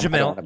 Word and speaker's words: Jemele: How do you pronounce her first name? Did I do that right? – Jemele. Jemele. – Okay, cool. I didn't want to just completely butcher that. But nope, you're good.